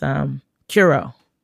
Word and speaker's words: curieux: It's Curo. So basically, Curo is French for curious, and it's spelled C-U-R-I-E-U-X.